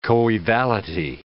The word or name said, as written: coevality